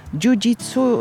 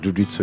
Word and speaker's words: jiu-jitsu: Jiu-jitsu, tutaj taką mam dywagację i wątpliwość, jak się powinno wymawiać? – Może być i tak, jiu-jitsu jest dobrze, może być po portugalsku jiu-jitsu, także wszystko jest okej.